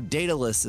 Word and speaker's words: Daedalus: So Daedalus is how it's pronounced.